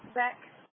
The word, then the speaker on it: Beck: Beck.